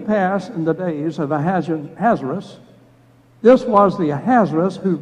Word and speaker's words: Ahasuerus: I just pronounce it Ahasuerus. That is one of the acceptable pronunciations. There are others who say Ahasuerus, and others who say Ahasuerus. But I'm going to use Ahasuerus, and that's the easiest for me to pronounce.